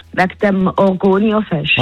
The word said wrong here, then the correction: Argovia-Fest: Also, der Roger hat es sicher mal richtig gesagt. Argovia-Fest.